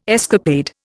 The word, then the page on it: escapade: Indian